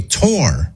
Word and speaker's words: tour: And Leah says, when you go to look at a house, you take a tour, rhymes with four. Or if you go on vacation to a famous place, you tour it. That makes you a tourist, not a tourist. No, Leah. Sorry. I know a lot of people believe that. – But it's also not tur. You don't take a tur.